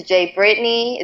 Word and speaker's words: J Brytni: This is Jay Brittany. It's spelled J-B-R-Y-T-N-I.